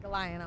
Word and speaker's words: Galion: I say that wrong every time. But Galion, Ohio.